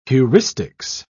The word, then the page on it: heuristics: British English